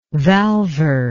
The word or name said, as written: valvar